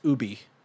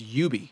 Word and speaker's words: Ubisoft: There are multiple ways to pronounce Ubisoft. Now, Brandon? – I say Ubisoft. – I say Ubisoft. Now, we've searched the Googles, and Google says you can pronounce it either way. They're both fine. Apparently, some CEO said it, I guess. But if you're a true Frenchman, it's Ubi. And if you're an Englishman, it's Ubi. – If you just listen to the people who actually work there, they say Ubisoft.